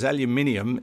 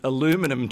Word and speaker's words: aluminium: Now, we could be in trouble here because aluminium is the way we say it and spell it. Do they call it aluminum 26 in America? – I'm sure they would, yes, that's right.